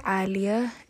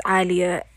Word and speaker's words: Alia: So, originally, my name is Alia. It's an Arabic derived name. You know, I'm Muslim and I am Somali, so my name, like, it's Alia.